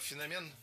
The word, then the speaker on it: феномен: Дело в том, что в эталонном словаре, рекомендованном Министерством просвещения, написано, что у нашего слова два значения. И от значения зависит ударение. Феномен – это научный термин. А слово «феномен» имеет уже более широкое значение. Его употребляют, когда речь идет о чем-то уникальном, в том числе и о человеке.